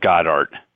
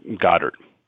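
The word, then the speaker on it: Godard: His name is Michael, people pronounce it Goddard. It's really pronounced Goddard.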